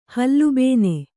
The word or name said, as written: ಹಲುಬೇನೆ